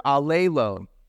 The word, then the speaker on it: allelon: Allelon is how you pronounce it. Now you've learned, everybody say allelon.